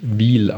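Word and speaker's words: Wil: da wird tatsächlich Wiel ausgesprochen, nicht Will oder ähm, so wie ihr das da äh, genannt habt.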